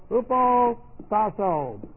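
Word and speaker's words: Hupo tasso (ὑποτάσσω): Hupo tasso. This is H-U sound. P-O-T-A-S-S, long O.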